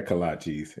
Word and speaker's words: kolaches: Do I say it right? Kalachis or Kalachis? – Yeah, Kalachis. It don't matter what you call them, as long as you call them good.